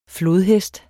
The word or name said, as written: flodhest